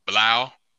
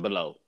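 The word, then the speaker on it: Blough: Blau, not Blau. – David Blau. – David Blau. People gonna pronounce it below, but it's.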